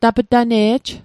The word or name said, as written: tap'tane'j